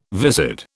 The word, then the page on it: viscid: British English